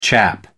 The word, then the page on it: chap: American English